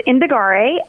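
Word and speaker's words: Indagare: What about Indigare? Am I pronouncing that right? – Yep, it's Indigare.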